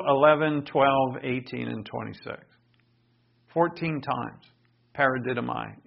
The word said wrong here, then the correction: paradidomi: is used. Paradidomi, it should be pronounced.